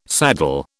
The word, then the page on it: saddle: British English